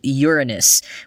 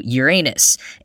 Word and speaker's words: Uranus: But Slate points out that even Uranus isn't technically the correct pronunciation. Quoting again, for some reason along the way, the spelling became Latinized to Uranus, and the pronunciation seems to have followed suit. It's not clear why this non-conforming nomenclature took on the Latinized pronunciation when the name was selected, when, in keeping with tradition, our seventh planet should really be called Ouranos, which would really not lend itself to such laughter.